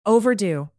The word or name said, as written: overdue